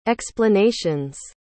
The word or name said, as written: explanation's